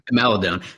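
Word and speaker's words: Maledon: Maldon. I don't know how to say his last name. – Maladone. So we always said Maladone also, but we found out it is Teo Maladone.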